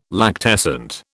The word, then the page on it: lactescent: British English